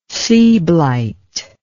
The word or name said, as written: seablite